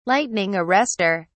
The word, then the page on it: lightning arrester: British English